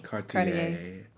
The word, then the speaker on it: Cartier: Is that how you say it? Or Cartier? – Cartier.